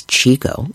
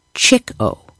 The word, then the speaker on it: Chico: Well, though many pronounce the nickname as Chico, as I've been doing, it was originally pronounced Chick-O, a nickname he earned because of how much he liked to chase girls.